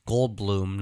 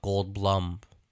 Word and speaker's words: Goldblum: His surname is often mispronounced due to its spelling, as he pointed out on a talk show, that his name is pronounced Goldblum, not Goldblum.